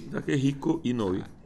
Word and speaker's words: Takehiko Inoue: O autor do Vagabond é o Takehiko Inoue, que é um animal. Eu não sei como é que fala, tá? – Takehiko Inoue.